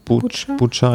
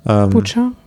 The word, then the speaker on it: Bucha: Also die Bilder jetzt aus, wie spricht man es aus? – Bucha einfach. – Bucha.